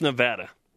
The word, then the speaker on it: Nevada: It's pronounced Nevada.